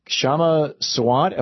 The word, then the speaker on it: Kshama Sawant: Kshama Sawant, am I saying that right? – Yes.